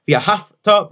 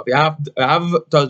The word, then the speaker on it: v'ahavta: Again, you saw it via hafta versus via havta. Via havta is the way to say it.